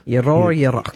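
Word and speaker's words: Euro-Yroke: How do you say Euroyarok? – Euroyarok. – Yeah, yeah. That's it. You did it.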